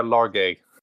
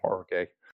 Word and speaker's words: Larguet: Nasser Larguet. Larguet. You want to pronounce it in the English way, yes, Larguet.